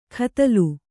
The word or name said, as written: ಕದಲು